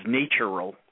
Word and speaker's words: natural: Or like I like to repronounce it as natural law, and keep the A as a long A rather than going to the short A, because then we somehow separate natural from nature, and it's not.